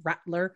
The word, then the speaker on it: Radler: It's spelled R-A-D-L-E-R. It is, I know it of German descent, so Rattler, more of a T sound, which is like a blonde lager, or am I saying the right thing there?